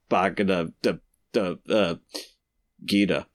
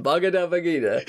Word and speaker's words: Bhagavad Gita: As it says in the Bhagavad Gita. I said that right. – Yes, yeah, you got it. The Bhagavad Gita.